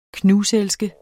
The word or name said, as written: knuselske